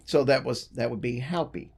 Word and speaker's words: Hopi: And the pronunciation I looked up said more like Haupi instead of Hopi.